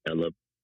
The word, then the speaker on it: Elib: I go by the name of Elib, E-L-I-B.